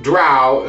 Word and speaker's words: drow: Ah, so it is definitely pronounced drow and then not as in cow and not dro as in...